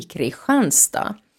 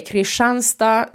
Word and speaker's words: Kristianstad: Eh, by the way, Kristianstad ska uttalas Kristianstad och inte Kristianstad eftersom det kommer från den danska kungen Kristian den fjärde och Kristian eh, uttalas på detta vis i danska.